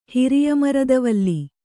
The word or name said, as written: ಹಿರಿಯಮರದವಲ್ಲಿ